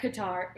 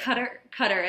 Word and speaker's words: Qatar: It's Qatar, I think, is how they pronounce it, but everybody in the U.S. says Qatar, so.